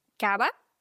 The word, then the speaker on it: cava: Dann kannst du sagen, nos trae una copa de cava. Nos trae una copa de cava. Und genau, das V wird wie ein weiches B ausgesprochen.